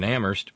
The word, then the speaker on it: Amherst: I deliver in Amherst, where the H is silent, and we haven't gotten first-class flats or magazines for 5 days running now.